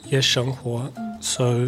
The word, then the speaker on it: 夜生活: 夜生活。So nightlife in New Zealand is quite limited,